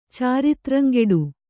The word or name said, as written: ಚಾರಿತ್ರಂಗೆಡು